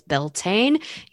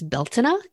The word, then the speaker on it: Beltane: So now we're coming up to my second favorite Wheel of the Year celebration, and that is Beltane. You may also hear it pronounced as Beltane if you are Irish.